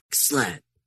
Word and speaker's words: SLED: Yeah, you have to say it like SWAT. You're basically saying SWAT, but you're saying SLED instead.